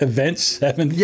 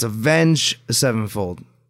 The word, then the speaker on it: Avenged Sevenfold: What did I call it? Event Sevenfold? – Yeah, you called it Event Sevenfold. Maybe I did say it wrong. I know I have speaking issues sometimes. But yeah, no, it's Avenged Sevenfold.